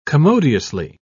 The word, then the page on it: commodiously: British English